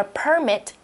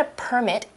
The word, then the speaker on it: permit: So when we get a permit, it permits us to do something. Permit. That is the verb form of this word. And it's pronounced differently. Permit, permit.